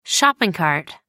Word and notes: shopping cart: British English

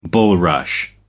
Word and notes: bulrush: American English

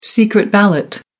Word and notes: secret ballot: American English

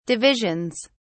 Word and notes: divisions: British English

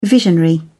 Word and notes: visionary: British English

